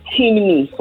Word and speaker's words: Temne: I'm going to spell it. T-E-M-N-E. Timni from Freetown, Sierra Leone, West Africa.